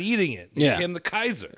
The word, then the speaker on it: Caesar: Soft C's, Caesar? It's Kaiser. That's where the modern Kaiser comes from.